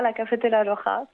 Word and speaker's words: La Cafetera Rora: C'est comme ça qu'on prononce ? – C'est ça, La Cafetera Rora.